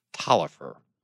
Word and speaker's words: Taliaferro: It's it's spelled Taliaferro, T A L I E F F E R O. It's pronounced Talifer.